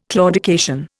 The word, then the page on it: claudication: Indian